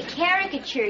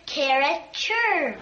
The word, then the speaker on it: caricature: I'm going to caricature you this afternoon after school. – You said it wrong again, Buffy. It's caricature.